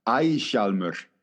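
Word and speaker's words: Ægishjálmur: Do you want to pronounce it properly? Since I probably butchered it. – Yeah. Eishalmur.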